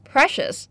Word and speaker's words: precious: Precious, the stress is on the first syllable, precious similar to the word valuable，指珍贵的、宝贵的。比如什么东西是珍贵的呢？Time is precious,